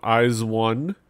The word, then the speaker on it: IZ*ONE: The ladies of IZ*ONE, yes, that's how you pronounce it, IZ*ONE. What was it? IZ*ONE. It's not IZ*ONE.